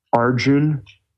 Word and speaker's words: Arjuna: I believe he's Arjun, which is spelled Arjuna, A-R-J-U-N-A. Usually I hear it pronounced, the A is silent.